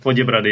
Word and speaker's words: Poděbrady: So you live, your town's called Poděbrady, right? – That's right. Poděbrady, yeah.